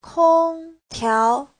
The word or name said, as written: kong1tiao2